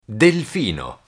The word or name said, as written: delfino